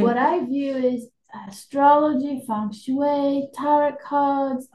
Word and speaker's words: feng shui: Okay, so at the beginning, we talked a little bit about feng shui. Is it the correct pronunciation? – Yes.